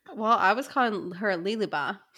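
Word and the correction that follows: Lehabah: but it's Lahaba.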